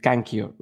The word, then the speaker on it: Kankyo: Is it Kankyo, right? – Thank you.